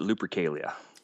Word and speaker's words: Lupercalia: So how do you pronounce it? Lupercalia. – We pronounce it Lupercalia.